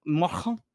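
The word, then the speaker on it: Marché: He said Vintage Market. – Is that how it's pronounced? – How do you say it? – Masha. – Masha. – Spelled M-A-R-C-H-E. That's how you spell market. – C-H-E? – Yeah. You look it up. – That's not how they spell. It's not spelled like that. – I swear to God. – It's not spelled like that. – Look it up. I'm looking it up. It's French as fuck. – Macha? – Yeah, macha.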